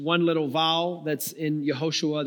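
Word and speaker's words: Yehoshua (יהושע): So in Hebrew, the name Joshua is pronounced Yehoshua.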